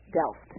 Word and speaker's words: Delft: Delft.